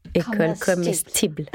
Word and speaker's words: École Comestible: École Comestible.